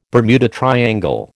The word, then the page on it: Bermuda triangle: American English